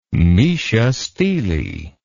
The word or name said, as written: meshastele